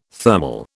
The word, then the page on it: thermal: British English